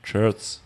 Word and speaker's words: Shirts: Das Wort nicht aussprechen. – Shirts heißt das. – Wie Jerks. – Nur mit Jerks. Shirts.